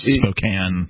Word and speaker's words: Spokane: And we have been talking about this article from several sources, but this one is from the Spokesman Review out of Spokane, or Spokane, Washington, if you want to mispronounce it. – Spokane. – Yeah, that's the mispronunciation. Yeah, it's Spokane when you're there.